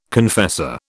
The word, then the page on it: confessor: British English